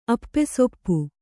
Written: ಅಪ್ಪೆಸೊಪ್ಪು

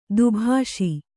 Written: ದುಭಾಷಿ

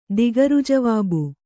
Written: ದಿಗರ್‍ಜವಾಬು

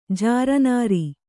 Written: ಜರೆನೆರೆ

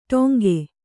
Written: ಟಂಕಿ

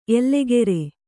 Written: ಎಲೆಗಾರ

